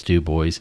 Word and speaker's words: Dubois: You know, they had the fact that a lot of that stuff was made in French Lick or in Dubois or Dubois, however you want to pronounce it. I know it's Dubois, or Orange County.